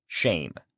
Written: shame

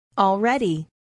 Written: already

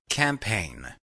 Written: campaign

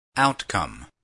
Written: outcome

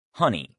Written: honey

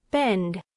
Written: bend

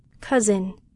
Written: cousin

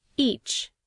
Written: each

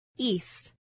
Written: east

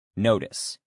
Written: notice

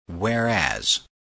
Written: whereas

